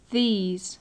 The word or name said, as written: these